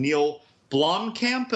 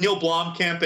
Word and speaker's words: Blomkamp: Neil Blomkamp, am I saying his name right? – Yeah. – With Neil Blomkamp, and he's the guy who did District 9, yes, Elysium.